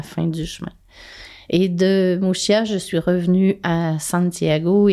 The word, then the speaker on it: Muxía: De Finistère et de Muxía. Muxía, ça s'écrit M-U-X-I-A.